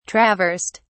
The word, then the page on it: traversed: British English